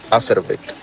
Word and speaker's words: Haverbeck: Sí, pues la B corta que se dice en alemán se pronuncia como F. Entonces, Haverbeck.